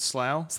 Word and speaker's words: Slough: Miracle cat found in sofa at Slow Way Centre. I think it's pronounced slow. Might be sloth. – Slough. S-L-O-W. – Is it slough? – Slough.